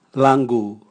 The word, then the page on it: langu: Raga